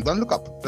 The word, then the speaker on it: Don't Look Up: Otra, ahora que estoy diciendo el look, el otro tráiler, no sé si ambos lo vieron también, es el Don, Look Up. A ver, lo dije bien, Don't Look Up.